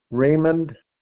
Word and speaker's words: Raymond: Raymond. R-A-Y-M-O-N-D, mm-hmm. – Mincheski, M-E-N-C-H-E-S-K-I.